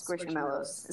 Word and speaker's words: Squishmallow: Dude, we got, how do you pronounce squishmallows? Is that how you... – Squishmallows? – Squishmallows? – Yeah, like marshmallow, squishmallow.